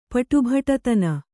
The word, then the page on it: ಪಟುಭಟತನ: Kannada